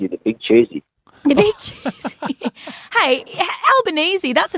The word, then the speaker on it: Albanese: you're supposed to say his name like Albanese, like Bolognese.